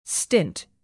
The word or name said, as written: stint